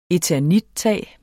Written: eternittag

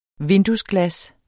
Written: VINDUESGLAS

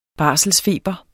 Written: barselsfeber

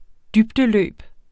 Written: dybdeløb

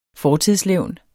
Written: fortidslevn

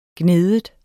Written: gnide

